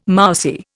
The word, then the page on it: mousy: Indian